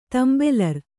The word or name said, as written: ತೆಂಬೆಲರ್